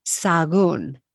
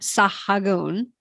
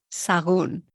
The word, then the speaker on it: Sahagún: Okay, here's another one that goes astray, because it's got an H, which we'll see is silent, and it's got an accent mark. So I say this one, Sahagún. – Mm-hmm. Sahagún. – Sahagún. Forget the H. So it's not Sahagún. No. Sahagún.